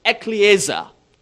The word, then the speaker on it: ekklesia: The word church comes from a Greek word which is transliterated into English when pronounced ekklesia. Ekklesia. Two words. Ek, E-K, out of.